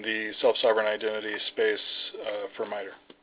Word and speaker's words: MITRE: I thought you had been here before, but it's pronounced MITRE, right? – That's right, yes.